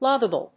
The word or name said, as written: loggable